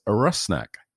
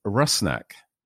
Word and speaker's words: Rusnak: So that you can all be better prepared for my goal-scoring announcements this season, the pronunciation of our new designated player's name is Rusnak, Albert Rusnak.